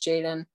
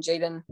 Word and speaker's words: Jadon: So if anyone wants to find me, Instagram, my username is Jadon Brooks with 3 O's and like 2 S's on the end, I think. So pretty confusing, but just type in Jadon Brooks, and Jadon is spelled J-A-D-O-N, not E-N.